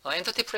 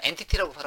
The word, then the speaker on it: entity: 자, 그러면 이제 다시 인덱스 페이지 다시 한번 실행시켜서 데이터를 한번 보면 자 엔터티 프레임워크를 사용하게 되면 이런 게 편하다는 거죠. 이런 게 편하다는 거죠. 그래서 이제 저는 엔티티라고 발음하지 않습니다. 엔터티 프레임워크라고 꿋꿋하게 발음합니다.